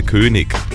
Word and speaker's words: König: Sicherlich in Süddeutschland, Österreich und der Schweiz sagen die Leute auf der Straße König oder ewig. In der Standardaussprache ist dies aber ein absoluter Fehler, außer wenn es sich um ein Mundartgespräch handelt. Das IG am Wortende muss immer ICH sein.